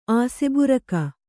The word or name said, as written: ಆಸೆಬುರಕ